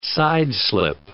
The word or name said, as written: sideslip